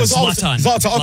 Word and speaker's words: Zlatan: Help me out. Zoltan. – Yeah, I should call him Zoltan. – Zlatan. Okay, Zlatan. Even the beef between, you can tell those two guys do not like each other.